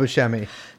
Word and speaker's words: Buscemi: It is spelled Buscemi.